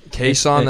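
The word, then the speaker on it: Chaisson: Kayson, that's how you say it.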